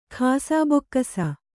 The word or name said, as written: ಖಾಸಬೊಕ್ಕಸ